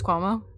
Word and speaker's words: Cuomo: Like, on, is it the Cuomo? Is that how you say it? Cuomo? – Chris Cuomo? Yeah.